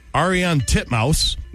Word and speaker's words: Ariane Titmus: Pronounce it the way it's spelled. – Ariane Titmus. Titmus. – There you go. – Is that how you say it? – That is legitimately how you say it.